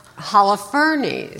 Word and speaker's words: Holofernes: Yeah, by cutting off the head of Holofernes.